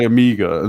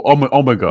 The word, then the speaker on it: Omega: That was annoying, because some maths teachers would say Amiga, and some maths teachers would say Omega. Or Omega. – Omega, if you say it properly.